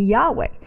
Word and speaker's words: Yahweh: Scholars are saying now that probably the correct way to say God's personal name would be Yahweh.